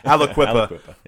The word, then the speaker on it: Aliquippa: Aliquippa. Did I pronounce that right? – I'm sounding like a New Jersey guy. – Aliquippa. Yeah, the New Jersey guy showing up here.